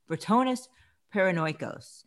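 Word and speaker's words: Ratones Paranoicos: On Netflix, there's something called Ratonus Paranoicus. I'm saying that wrong. Ratonus Paranoicos, the band that rocked Argentina.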